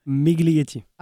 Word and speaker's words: Miglietti: Miglietti.